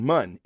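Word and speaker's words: Muun: Darth Plagueis is, he was a Mun, which was a very tall, I pronounced that right, Jason, Mun, M-U-N-N, I can do that shit.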